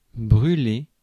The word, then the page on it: brûlé: France; [bʁy.le]